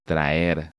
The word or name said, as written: Traer